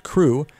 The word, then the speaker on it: CHRU: The Carolina Harm Reduction Union, known as CHRU, pronounced CRU, is a student-led program that provides life-saving harm reduction resources to the public of Chapel Hill.